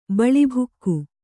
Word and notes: ಬಿಳಿಬೋಗಿ: Kannada

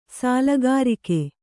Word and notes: ಸಾಲಗಾರಿಕೆ: sālagārike

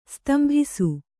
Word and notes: ಸ್ತಂಭಿಸು: stambhisu

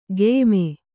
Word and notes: ಖಾಯಮ್ಮು: khāyammu

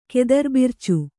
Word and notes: ಕೆದರ್ಬಿರ್ಚು: kannada

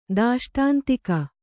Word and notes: ದಾಷ್ಟಾಂತಿಕ: dāṣṭāntika

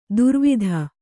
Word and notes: ದುರ್ವಿಧ: kannada; durvidha

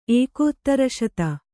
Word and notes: ಏಕೋತ್ತರಶತ: Kannada

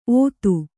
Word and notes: ಔತಿ: Kannada

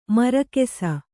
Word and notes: ಮೂರ್ಖಿಸು: mūrkhisu

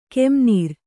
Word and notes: ಕೆಂನೀರ್: kannada

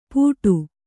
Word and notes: ಪೂಟು: pūṭu

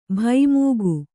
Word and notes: ಭೈಮೂಗು: bhaimūgu

